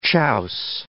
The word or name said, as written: chiaus